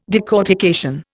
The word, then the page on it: decortication: Indian